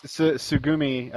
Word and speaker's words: Tsugumi: Tsugumi, I don't think they pronounce the T.